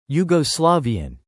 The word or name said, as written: Yugoslavian